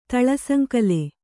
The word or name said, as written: ತಳಸಂಕಲೆ